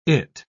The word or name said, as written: it